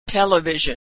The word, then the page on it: television: zh